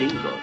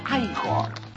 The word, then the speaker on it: Igor: Frankenstein, usted debe de ser Igor. – No, se pronuncia Igor.